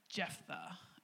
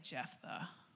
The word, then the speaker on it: Jephthah: And so enter Jephthah. Everybody say Jephthah.